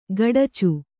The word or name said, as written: ಖಡಚಿ